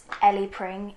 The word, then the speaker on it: Ellie Pring: My name is Ellie Pring. It is spelled E-L-L-I-E-P-R-I-N-G.